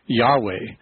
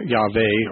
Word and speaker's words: Yahweh: though it's not quite the right Hebrew pronunciation, because in Hebrew there's no J sound. Actually, most scholars would agree that the name should be rendered verbally Yahweh. Yahweh or Yahweh would be a better way to pronounce it.